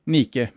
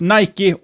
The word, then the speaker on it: Nike: Nike uttalas Nike or Nike.